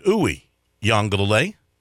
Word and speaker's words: Uiagalelei: Here at the very end, I have figured out how to say his name exactly right. Uiagalelei. Right? The only one I have a problem with now on the Clemson team, remember they had all these names, is Rook.